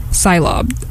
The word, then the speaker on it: Cylob: Okay, so this next song that I'm going to play is called I Left My Heart in Syntax Error by Cyclob, from their album Cylobian Sunset. I mispronounced their name. Cylob. I don't know if I said Cylob or Cyclob. I thought I said Cyclob.